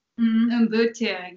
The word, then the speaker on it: Ubuntu: Is it Ubuntu or? – Ubuntu, yeah.